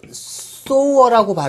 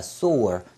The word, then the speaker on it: sewer: 자, 그런데 거기에서 er이 붙었기 때문에 뭐 많은 사람, 뭐 많은 것 해가지고, 자, 하수도, 하수구가 되는데, 그, sower라고 발음을 하면은 sower, so에 대한 뭐 많은 사람이 되겠죠? so라고 발음하면 무슨 뜻이에요? 자, 소우자 생각하라 그랬죠? 그래서 자, 소가죽을 갖다가 바늘로 이렇게 꿰매는 거 생각하라고 했던 거 기억나시죠? 고 1, 2 수준에서 했었던 단어입니다. 그러면 꿰매는 사람, 즉 재봉사가 됩니다. 자, sewer. 자, sewer라고 읽으면은 하수구, 어, 하수도 이런 뜻이 되고.